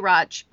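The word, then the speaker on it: Radch: I usually say ratch, but yeah.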